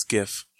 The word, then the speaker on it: GIF: It's GIF.